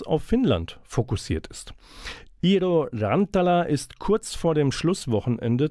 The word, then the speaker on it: Rantala: Und auf dem Song gleich, den wir einspielen, hört ihr auch Iro Rantala. – Das klingt, wenn ich es nicht zu westfälisch ausgesprochen habe, nicht Spanisch, sondern Finnisch. Und